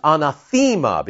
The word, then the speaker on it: anathema: Anathema, not anathema. The correct pronunciation is anathema because there's an eta, not an epsilon in the middle, but an eta. So it gives it that long E sound in Greek.